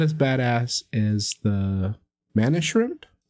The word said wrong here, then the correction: mantis shrimp: Did I say it right? – It's the mantis shrimp.